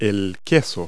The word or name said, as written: cheese